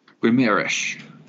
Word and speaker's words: Guimarães: I can't pronounce it. Bruno Guimarães. Is that right? In midfield as well. – As of now, let's make that our pronunciation that we use on this podcast. – Guimarães.